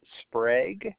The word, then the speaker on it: Sprague: My first name is Max, spelled M-A-X. Last name is Sprague. That's spelled S-P-R-A-G-U-E.